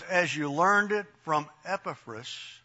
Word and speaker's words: Epaphras: And this guy, Epaphras, and it's Epaphras, the emphasis is on the first syllable, as in Septuagint.